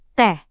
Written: teh

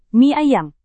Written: mie ayam

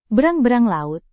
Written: berang-berang laut

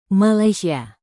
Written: malaysia

